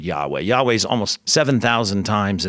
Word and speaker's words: Yahweh: This is what's called the Tetragrammaton, these four Hebrew letters in the Old Testament, and we put some vowels with those Hebrew characters, those Hebrew consonants, and we pronounce it as best we know. We pronounce it Yahweh.